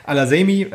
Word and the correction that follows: Al-Hasami: ähm, oder wie Yibi, ich wollte einmal nur den Namen aussprechen. – Al-Hasami. Al-Hasami.